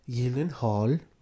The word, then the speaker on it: Gyllenhaal: Gyllenhaal? That's actually how you say it. – Is it really? – He goes, Gyllenhaal. Like, that's how it's actually pronounced.